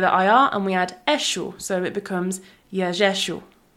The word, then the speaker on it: eixo: Eshu. – Yeah, eshu. So it's spelt E-I-X-O, eshu.